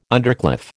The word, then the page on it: undercliff: American English